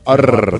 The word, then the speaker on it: Orbán: ¿Se acuerda que le dije Orbán o Urban? – Y usted me dijo Orbán. Así, profesor. Orbán. – Pronuncilo bien, exacto.